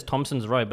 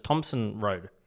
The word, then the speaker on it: Thompson Road: Everyone says Thompson's Road, but it's Thompson Road. – Yeah, there's no S.